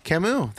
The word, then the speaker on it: Camus: Albert Camus. – Camus. There you go. – Nailed it.